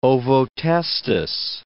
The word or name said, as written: ovotestis